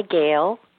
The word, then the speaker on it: Gehl: It's Soundworks by Gale. G-E-H-L is the way my last name is spelled.